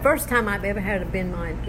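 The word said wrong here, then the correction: bánh mì: in benmai, how do you say it? Benmai? Banh mi.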